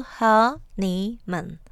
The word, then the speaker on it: honeymoon: There are three syllables in honeymoon, with a stress on the first syllable, honeymoon.